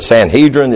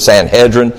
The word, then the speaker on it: Sanhedrin: Is it Sanhedrin? Is it Sanhedrin? And they really were helpful. They said it's both.